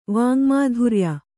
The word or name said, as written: ವಾಙ್ಮಾಧುರ್ಯ